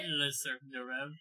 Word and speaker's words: Le Cirque des Rêves: Did I say that right? – Le Cirque des Rêves, yeah.